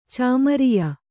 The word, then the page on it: ಚಾಮರಿಯ: Kannada